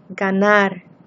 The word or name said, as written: ganar